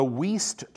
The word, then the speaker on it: Wuest: The Weiss translation, if you ever want to look that up, it's spelled W-U-E-S-T.